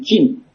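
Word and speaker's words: Qin: và họ gọi là nước Tần là trong cái tiếng Anh của Âu Mỹ nói chung, Anh Pháp Đức là đọc là chữ Chin.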